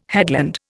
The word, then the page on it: headland: Indian